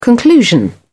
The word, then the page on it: conclusion: British English